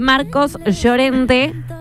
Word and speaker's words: Marcos Llorente: Sí, y como estamos hablando de artistas del otro lado del charco, voy a contar las declaraciones de Marcos Llorente.